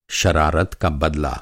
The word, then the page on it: बदला: masculine noun